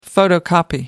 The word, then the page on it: photocopy: American English